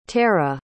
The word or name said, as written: terah